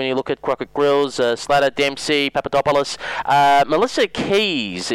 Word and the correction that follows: Kuys: is the one to. Oh, pardon me, Melissa Kuys.